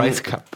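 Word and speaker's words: Rajskub: und die Schauspielerin ist Mary Lynn Reiskup. Reiskup? Scheiße, das kann ich jetzt nicht aussprechen. Wie findest du Mary Lynn Reiskup? Reiskup? – Ähm, Rice Cup.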